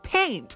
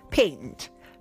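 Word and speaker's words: P8NT: Speaking of bananas, B-A-N-A-N-A-S, Gwen Stefani is launching her own makeup line called Paint, but it's spelled P and then the number 8 and then N-T. So patent, paint, paint.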